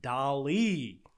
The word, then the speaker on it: DALL-E: and a nonprofit company called OpenAI, which is developing an artificial intelligence called DALL-E. Kind of rhymes with WALL-E.